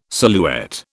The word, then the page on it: silhouette: British English